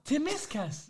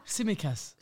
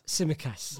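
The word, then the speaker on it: Tsimikas: To Miss Cass. – You butchered that one still. – Timiskas. – Oh! – Brada? – Simikas. – Simikas. – There you go.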